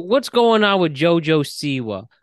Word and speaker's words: JoJo Siwa: So, you know that celebrity JoJo Siwa? Is that how you pronounce it? – JoJo? Yeah. – It is how you pronounce that.